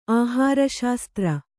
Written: ಆಹಾರಶಾಸ್ತ್ರ

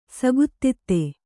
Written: ಸೂಕ್ತತೆ

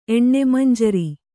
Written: ಎಣ್ಣೆಮಂಜರಿ

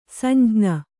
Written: ಸಂಜ್ಞಿ

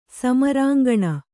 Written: ಸಮರಾಂಕಣ

